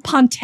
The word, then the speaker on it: ponte: Ponte? Is it like? – There we go. Yes, say with an accent.